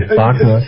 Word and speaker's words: Wagner: it's most famous for its Wagner Festspiele. – Okay. – Wagner, you know, the composer? – Sure, Richard Wagner. Or Wagner. – Richard Wagner, yeah. I always pronounce it in the German, Wagner.